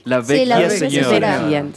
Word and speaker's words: Vecchia Signora: como lo fue el caso de Vecchia Signora, ¿se dice así? – La Vecchia, Signora Entonces, ellos no lo quieren, pero esperemos a ver si la Roma sí, sí confirma esa información.